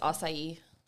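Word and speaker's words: açaí: This is pronounced acai. – What? Yeah, I said a Kai. You're not the first person to say that.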